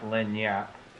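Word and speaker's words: lagniappe: It is actually pronounced Lanyap.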